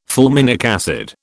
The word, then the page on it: fulminic acid: British English